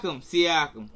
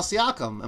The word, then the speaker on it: Siakam: Siakam. – Pascal Siakam.